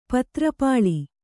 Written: ಪತ್ರಪಾಲಿ

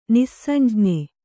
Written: ನಿಃಸಂಜ್ಞ

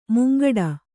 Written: ಮುಂಗಡೆ

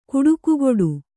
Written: ಗೌಟಿಕೆಗಟ್ಟು

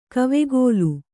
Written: ಕಾವಕೂಲ

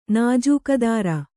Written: ನಾಜೂಕದಾರ

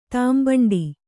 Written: ತಿಂಬಂಡ

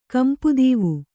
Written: ಕಂಪುದೀವು

